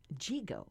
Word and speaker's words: Yigo: He's from Jigo, right? That's yes. – How you say it, spelled Y-I-G-O.